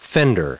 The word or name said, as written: fender